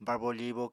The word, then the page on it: barbo-fibo: Apatani